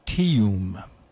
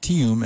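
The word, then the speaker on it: Teyum: When he moved to North Carolina many years ago, he noticed that the locals were actually stretching his first name into two syllables, and Tim became Teeum. And so he decided to adopt Teeum as his performing name.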